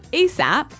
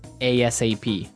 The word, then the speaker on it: ASAP: So it's an acronym, and you can say it like ASAP or ASAP.